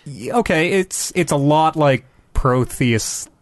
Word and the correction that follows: Prometheus: or that's what, I'm not saying it right. What is the name of that movie? – Prometheus. – Thank you, yes.